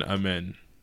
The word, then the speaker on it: Amen: Is it Amon or Amen? How do you say his name? – There's a Sar and then Amen. – Okay, Amen. They're probably going to get Amen Thompson at four.